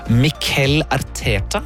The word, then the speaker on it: Mikel Arteta: Det står for Arsenal-manager Mikel Arteta.